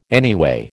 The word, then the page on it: anyway: American English